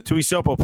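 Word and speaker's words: Tuiasosopo: She was the funny thing is that it's if you remember the Tui Sopo name, is that how you say it? – Yeah. Tui Sopo played for his dad, played like for the Patriots.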